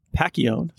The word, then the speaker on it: Pacchione: His name is Mike Pacchione.